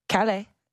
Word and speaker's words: Kalle: Kalle. It's definitely Kalle.